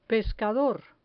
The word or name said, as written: pescador